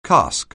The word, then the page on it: cask: anglais